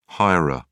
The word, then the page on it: hirer: British English